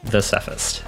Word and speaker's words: Thesephist: You may know him as Thesephist, or T-H-E-S-E-P-H-I-S-T. I think it's Thesephist. Linus, how do you say that? – The Cephist, as in the English article, and then Cephist.